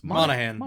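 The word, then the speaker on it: Monaghan: Cameron I've never known how to say his name. – Monahan. – I always want to say the G.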